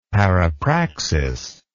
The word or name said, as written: parapraxis